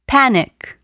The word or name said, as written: panic